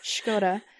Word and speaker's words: Škoda: This is perfect pronunciation. It's Škoda.